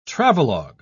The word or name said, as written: travelogue